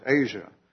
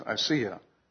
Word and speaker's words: Asiya: The lady's name, I would pronounce it Asia, but I think the proper pronunciation is Asiya Bibi.